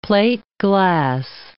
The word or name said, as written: plateglass